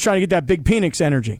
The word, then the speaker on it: Penix: Penix. – And like Pat McAfee said at the end of the clip, I thought I was pronouncing his name wrong or something. And they're like, nope, no you weren't.